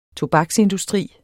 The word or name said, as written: tobaksindustri